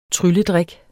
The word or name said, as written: TRYLLEDRIKKEN